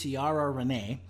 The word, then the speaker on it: Ciara Renee: I believe it is Ciara Renee. – Sure. – And, yeah, good. she that was spot on.